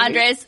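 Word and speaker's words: Andrzej: Andres Sapkowski. – It's like Andre, but Polish.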